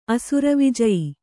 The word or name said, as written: ಅಸುರವಿಜಯಿ